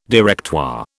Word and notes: Directoire: British English

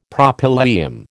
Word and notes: Propylaeum: American English

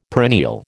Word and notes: perennial: American English

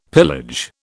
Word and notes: pillage: British English

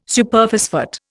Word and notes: Superphosphate: Indian

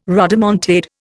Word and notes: rodomontade: Indian

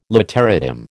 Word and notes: literatim: American English

